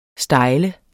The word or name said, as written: stejl